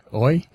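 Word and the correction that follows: Oui: is that We? – We, okay. Sorry about the mispronunciation of the French, but We Magazine and other regional national publications.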